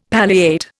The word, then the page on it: palliate: Indian